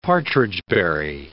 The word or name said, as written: partridgeberry